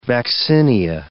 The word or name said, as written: vaccinia